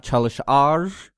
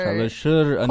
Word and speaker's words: çalışır: Right, so not çalışar. – Like çalışır. – Çalışır.